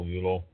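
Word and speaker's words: Ullo: And the, the whole list of them, eh, actually, uh, ULU, uh, I guess that's just pronounced ULO, Y, or uh, not Y, U L L O, ULO, uh, was one drop it the wand.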